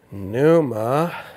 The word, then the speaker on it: Pneuma: The Holy Spirit in, in Greek is uh, that P is silent, like pterodactyl. Pneuma Hagios.